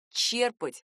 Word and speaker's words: черпать: Правильный вариант — только «черпать» с ударением на первый слог.